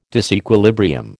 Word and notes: disequilibrium: American English

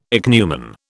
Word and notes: ichneumon: British English

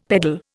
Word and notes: peddle: Indian

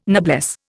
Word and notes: noblesse: Indian

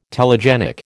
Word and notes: telegenic: American English

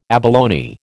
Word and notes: abalone: American English

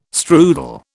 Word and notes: strudel: British English

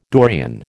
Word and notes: Dorian: American English

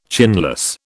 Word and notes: chinless: British English

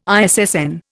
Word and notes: ISSN: Indian

